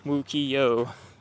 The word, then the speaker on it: Wukiyo: Wukio. – Six letters, three syllables, phonetic. – You spelled this for me last night, I forget. – Yeah, W-U-K-I-Y-O. – W-U-K-I-Y-O.